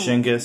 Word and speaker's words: Schenker: Schenker, sí, Schenker.